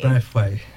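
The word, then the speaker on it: Braithwaite: How do you say his name? – Brathway.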